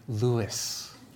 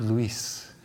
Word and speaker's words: Luis: And so I said, Mrs. Holloran, my name is actually Lewis, not Luis. Because in my head, I thought, you can't say that. You don't know how yet. It's okay. You tried. But go with Lewis. You could still spell it Luis, but just say Lewis.